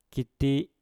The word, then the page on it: kiti: Mpur